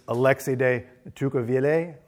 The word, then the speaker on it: Alexis de Tocqueville: And because he's French, and his name is Alexis de Tocqueville, and I think that's how you say it. But Alexis, or Alexi, is spelled A-L-E-X-I-S.